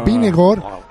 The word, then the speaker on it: Vingegaard: Vingegaard.